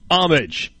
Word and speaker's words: homage: You need to look up the pronunciation of homage. The H is silent. The emphasis is on the first sound, om.